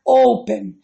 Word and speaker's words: open: A sílaba mais forte na pronunciation é open, não é open, é open. Então eu não vou duplicar. Mas, por exemplo, a palavra get, que escreve G-E-T.